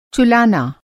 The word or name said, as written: चुलाना